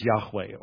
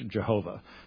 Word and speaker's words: Yahweh: that name is Yahweh, or as we slaughter it in English, Jehovah. It could not have been its proper pronunciation, but Yahweh is probably the best pronunciation.